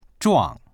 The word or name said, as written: zhuang4